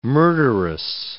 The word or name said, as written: murderess